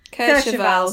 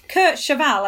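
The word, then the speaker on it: Kercheval: And then the other guest star, whose surname I never know how to pronounce. He was Cliff in Dallas. Ken Kovachal? Or Kurt Cheval? – Kurt Cheval. – Sorry, I've got my vowels there. Kurt Cheval, as in horse.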